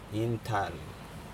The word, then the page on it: intal: Aneityum